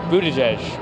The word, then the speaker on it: Buttigieg: Mas ele é tão novo na política que quase ninguém sabe pronunciar seu sobrenome. Esse eleitor que tem uma camiseta com a pronúncia dá uma forcinha. – Uh, it's Buttigieg.